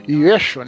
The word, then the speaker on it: Yeshua: Desse Yeshua, né? Como a gente como se fala na, em aramaico, ele realmente existiu ali.